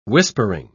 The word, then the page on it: whispering: British English